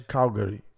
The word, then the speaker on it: Calgary: Calgary.